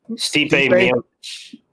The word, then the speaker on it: Stipe Miocic: Stipe Miocic.